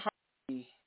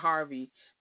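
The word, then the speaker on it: Harve: Oliver is not Harvey. His name is Harv. It's not Harvey. I'm saying the name wrong. It's Harv Pierre, the former president of Cohen's Bad Boy Entertainment.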